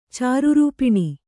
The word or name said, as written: ಚಾರುರೂಪಿಣಿ